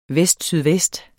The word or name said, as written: vestsydvest